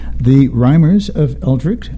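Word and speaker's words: The Rimers of Eldritch: The Rhymers of Eldritch, I hope I'm saying that correctly.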